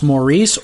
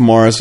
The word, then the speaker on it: Maurice: And by the way, is it pronounced Maurice or Morris? – Both. – Okay. – Yeah. And because I hear it used Kern to be refer to him as Morris Cole. – Well, it's Morris.